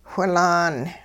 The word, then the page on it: whilaan: hupa